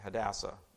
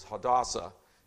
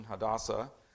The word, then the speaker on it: Hadassah: She's given a Persian name of Esther, and her Hebrew name is, I have been pronouncing it Hadassah, but I guess it is Hadassah, is the proper pronunciation, Hadassah.